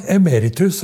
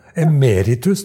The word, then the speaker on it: Emeritus: Men, men du har jo rett uttale, nå. Emeritus er lett trykket på rett plass nå. Ja, så det? Ja. Emeritus, det er det, det, det uttales.